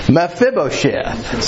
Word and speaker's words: Mephibosheth: How do you say this name? It's a big old biggin. So I did this. It's Mephibosheth. Mephibosheth. So let's say it together.